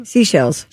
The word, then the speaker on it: Seychelles: Seychelles? Is that how it's said? – Seychelles. – Seychelles? No, it's S-E-Y-C-H-E-L-L. – I know, but they call it seashells.